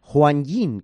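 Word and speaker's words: Huanyin (欢迎): Una de ellas es Huanyin, que es, como se dice, bienvenidos. Juan, o bueno, es como se debe de pronunciar, bienvenidos.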